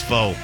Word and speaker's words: pho: Pho or Ramen? I love them both, but I'm gonna lean more towards pho. Or pho, I believe it's pronounced.